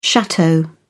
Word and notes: chateau: British English

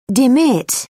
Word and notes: demit: British English

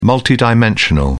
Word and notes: multidimensional: British English

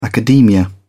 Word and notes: academia: British English

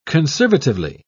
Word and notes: conservatively: British English

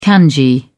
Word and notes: kanji: British English

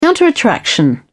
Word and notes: counterattraction: British English

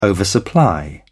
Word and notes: oversupply: British English

